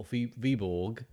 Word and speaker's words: Viborg: I wasn't sure whether to pronounce Viborg or Viborg. It's not, you don't really pronounce the G, do you? – Yes, Viborg. – Viborg. Viborg. Yeah. You kind of say the G.